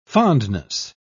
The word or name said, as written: fondness